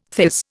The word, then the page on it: theirs: Indian